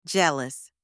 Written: jealous

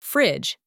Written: fridge

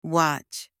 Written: watch

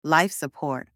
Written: life support

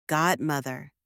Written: godmother

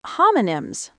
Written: homonyms

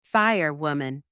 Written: firewoman

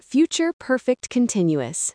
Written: future perfect continuous

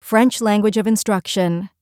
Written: French Language of Instruction

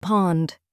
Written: pond

